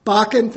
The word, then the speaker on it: Bakken: There are also, if you read today's Ames newspaper, there's an article about pipelines that Bakken company, I don't know if I'm pronouncing that right, B-A-K-K, Bakken, thank you.